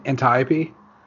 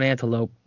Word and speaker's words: Antiope: Isn't it Antiope? – Yep. – Yes. – Okay. She is... What? – Said I was going Antelope.